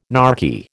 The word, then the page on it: narky: American English